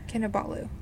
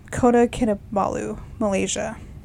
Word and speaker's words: Kota Kinabalu: Did I say that right? – Kinabalu? – Yeah. – Kinabalu. – Kota Kinabalu, Malaysia.